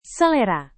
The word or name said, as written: selera